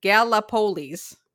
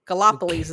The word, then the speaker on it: Gallipolis: And across the Ohio River is they pronounce it Gallipolis, Gallipolis is how I would do it.